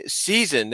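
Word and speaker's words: SEA3ON: Number nine, we will talk about Team Season. That's SEA numeral 3-O-N.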